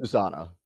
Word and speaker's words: Susana: We were talking in the pre-interview, and I know I butchered his name, was Susana. Am I saying that correct there? – Yes, Harley and Susana.